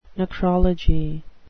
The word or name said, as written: necrology